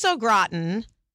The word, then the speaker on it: au gratin: potatoes au gratin.